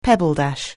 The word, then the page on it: pebbledash: anglais